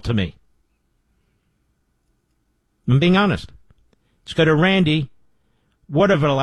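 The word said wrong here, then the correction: Watervliet: How do you pronounce that? – It's water volite. – I was close. – It's a Dutch word.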